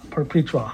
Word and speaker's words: Perpetua: Perpetua.